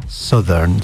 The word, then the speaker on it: Southern: Lógica formal sería Southern, pero pues cuando, when English zigs, zig, it zags. Entonces, como que claro, cuando creías que iba en una dirección, pues el inglés te va a tirar en la otra. Y pues, en este caso se pronuncia Southern. So, no diríamos Southern, sino Southern.